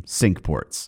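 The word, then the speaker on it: Cinque Ports: Now, there were five of these ports, hence the name sink ports, which is an English pronunciation of the old French term for five harbors.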